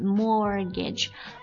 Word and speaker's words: mortgage: 那我们在英语当中还有一个单词也是表示按揭贷款，那就是我们之前有偶尔提到的这个 mortgage 这个单词 ,mortgage。我之前说过这个 T 是不发音的 ,mortgage,M-O-R-T-G-A-G-E, mortgage。